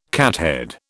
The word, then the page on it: cathead: British English